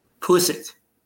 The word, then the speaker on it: Pulisic: Pulisic.